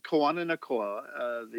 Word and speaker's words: Kawananakoa: Kawananakoa, the Hawaiian alphabet doesn't have as many letters as the English, but the pronunciation is pretty simple. It's somewhat similar to Spanish. So Kawananakoa, that's exactly how it's pronounced.